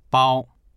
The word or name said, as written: bao1